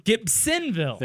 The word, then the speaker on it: Gibsonville: However, WD, he's mispronouncing it. There's no Z in Gibsonville. Gibsonville.